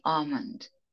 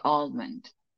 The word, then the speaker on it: almond: In British, they don't pronounce the L. They say almond. In American, they say almond.